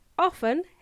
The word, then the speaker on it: often: The word often has a silent T and it's pronounced as often, but it's spelt as O-F-T-E-N, pronounced often.